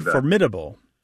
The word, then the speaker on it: formidable: It's also words like applicable becoming applicable, formidable becoming formidable, And the famous one, which actually has a UK-US divide, although usually there is no US-UK divide.